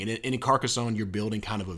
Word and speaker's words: Carcassonne: How do you pronounce it, Dean? – Carcassonne. – Carcassonne. You sound a little more, I don't know. – French? – Yeah, a little more French.